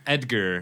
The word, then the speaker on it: Edgar: Edgar. But she pronounces it Egger.